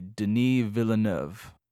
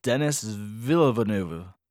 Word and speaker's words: Villeneuve: I'm going to say his name correctly, Denis Villeneuve. That's how you pronounce it in French. – Sure. – Or if you're American, Denis Villeneuve.